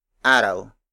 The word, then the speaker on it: araw: An example of a root word is araw.